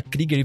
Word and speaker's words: Krueger: O pessoal sempre tem dificuldade porque o Fred Krueger tá muito difundido. É, na real, se fala Krieger e todo mundo sempre erra. Toda vez que vai sair no roteiro, tem que colocar, pronuncia Krieger com I.